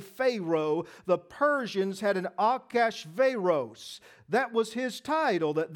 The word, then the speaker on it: Achashverosh: This is Ahasuerus, and this is the king of Persia. In the Hebrew, this is a four-syllable name, and it's actually pronounced Achashverosh. Achashverosh.